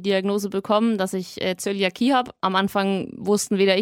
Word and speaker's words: Zöliakie: Das nennt sich Zöliakie, wenn ich das richtig ausgesprochen habe.